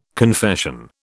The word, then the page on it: confession: British English